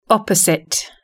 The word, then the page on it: opposite: British English